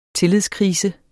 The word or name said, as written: tillidskrise